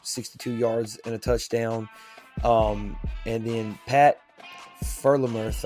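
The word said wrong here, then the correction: Freiermuth: I want to say that's how it's pronounced. God, I don't – some of these names are weird. – I think it's Frymuth. – Frymuth. Okay, cool. We'll go with that then.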